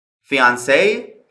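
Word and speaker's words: fiancée: Fiancé و fiancé که هر دو یک تلفظ دارن به معنی نامزد هستن. کلمه اول برای مذکر استفاده میشه و کلمه دوم برای مؤنث. این دوتا کلمه که در اصل کلمات فرانسوی هستن که وارد انگلیسی شدن توی تلفظ هیچ فرقی با هم دیگه ندارن، فقط اونی که برای مؤنث استفاده میشه آخرش یه دونه ای اضافه داره.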